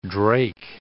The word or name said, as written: drake